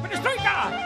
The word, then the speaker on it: Perestroika: ¡Perestroika! ¡Claro! ¡Pasiva! – Acento en la I.